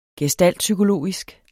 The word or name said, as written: gestaltpsykologisk